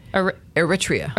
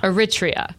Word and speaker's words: Eritrea: Eritrea. – Eritrea.